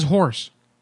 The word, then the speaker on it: Horse: My name is Horse.